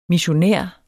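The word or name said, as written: MISSIONÆR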